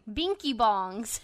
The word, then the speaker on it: Binky Bong: for their lightsticks, which are called Haley Blinky Bong. Oh wait, Binky Bongs. – Silent L. Binky Bong.